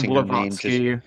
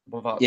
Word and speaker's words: Blavatsky: But Madame Blavatsky is like, I'm probably butchering the pronouncing Madame Blavatsky. That's what I've always heard it pronounced. – Blavatsky.